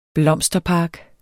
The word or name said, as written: blomsterpark